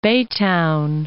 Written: Baytown

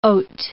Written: haute

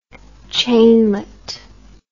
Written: chainlet

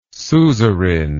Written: suzerain

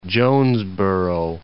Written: Jonesboro

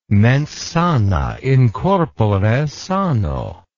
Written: mens sana in corpore sano

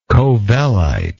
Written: covellite

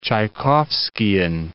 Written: Tchaikovskian

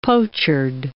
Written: pochard

